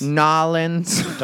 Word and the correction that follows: New Orleans: Don't fuck it up. Nobody says Nolens here either. It's one of the sticking points. It's New Orleans.